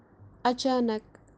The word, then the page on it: achanak: kashmiri